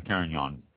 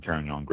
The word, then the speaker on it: Carignan: It is primarily a Carignan growing region, Carignan grape. Carignan, C-A-R-I-G-N-A-N. Just a lot of people see this grape out there in different wines here and there. If you do see the Carignan grape or Carignan wine, pick it up.